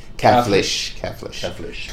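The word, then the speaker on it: Caflisch: You're going to have to help me on the pronunciation here. Max Kaflisch, who was a student of Chicholt's, as a matter of fact, in Switzerland.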